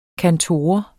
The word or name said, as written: kantor